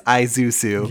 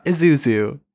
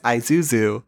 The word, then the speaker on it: Isuzu: And they were very specific on the pronunciation. Like, they got the brief from the brand. It is Isuzu. – Yeah. – It's not Isuzu. It is Isuzu.